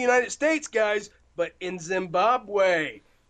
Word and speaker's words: Zimbabwe: Zimbabwe.